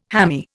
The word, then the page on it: hammy: Indian